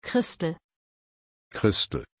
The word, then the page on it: christel: German